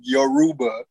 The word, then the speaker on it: Yoruba: I'm saying Robuda. I'm sorry, my bad. Oh. My glasses again. – Yo Buddha. – Yo Roba. Yoruba. That's how you say hello in Yoruba. Y-O-Y-O-R-U-B-A.